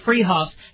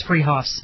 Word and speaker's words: Fruhoff's: It's spelled Fruhoff's, pronounced Freehoff's. They're at 33rd and Arapahoe in Boulder. That's Freehoff's.